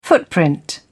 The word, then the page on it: footprint: British English